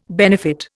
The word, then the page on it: benefit: Indian